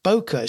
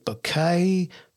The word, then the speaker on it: bokeh: Some people pronounce it bouquet, but that sounds more like flowers to me, and I'm not a flowers kind of guy. So for me, it's bokeh.